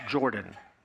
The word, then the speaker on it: Jordan: Years ago, Coach Jordan at Auburn University used to be so concerned with how people said his name that there was actually a sentence in the media guide that told you how to pronounce Jordan. Apparently in the Deep South, people like to say Jordan. He would point it out that it was like the river Jordan in the Bible.